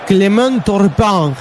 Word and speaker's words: Clément Turpin: Clément Turpin, el de francés. – Qué bárbaro, qué pronunciación, ¿eh?